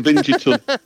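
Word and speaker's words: vingt-et-un: We'd pronounce it Vingitun. – Vingitun, but definitely not the Ving. – Spell it phonetically if anybody wants. – Yeah, if you say Ving, it makes me sound like I've got a nasty disease.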